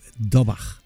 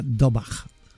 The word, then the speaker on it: Daubach: el apellido es, se escribe Daubach, pero pronuncia Dobach.